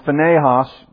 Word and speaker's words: Phinehas: And this that shall come upon your two sons, Hophni and Phinehas, or Phinehas is the correct pronunciation, shall be the sign to you.